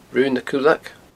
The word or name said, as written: Raon nan Cuileag